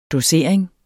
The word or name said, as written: dosering